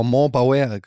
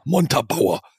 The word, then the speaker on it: Montabaur: Ich habe das jahrelang falsch ausgesprochen. Ich habe immer Montabaur oder so. Und dann habe ich das einfach deutsch ausgesprochen. Montabaur.